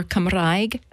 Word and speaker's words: Cymraeg: And Welsh, or Cymraeg, as it's pronounced, as it's said in Welsh, is on the uptick, and people have devoted themselves to that.